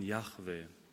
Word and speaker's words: Yahweh: Und das Wort Herr in Großbuchstaben im Alten Testament steht für Gottes heiligen Bundesnamen JHWH, ausgesprochen Yahweh.